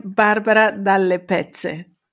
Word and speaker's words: Barbara Dalle Pezze: I am Barbara Dalle Pezze.